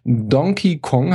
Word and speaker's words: Donkey Kong: Es war ein grandioses Jahr für die Wii U und es hat mit einem Knall angefangen, nämlich Donkey Kong, habe ich es richtig gesagt, Daniel? – Ja. – Ja, ich habe nicht Donkey gesagt, bitte ein Pluspunkt für mich.